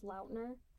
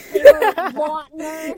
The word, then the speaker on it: Lautner: Yeah, did you know that, like, he said on his podcast that, like, his last name is actually pronounced Lautner? And that, like, everyone's just been getting it wrong? Yeah. – Okay, well, I do apologize for just calling him Lautner.